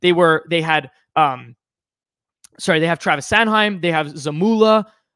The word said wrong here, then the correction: Zamula: I don't know how to pronounce his name properly. Zamula.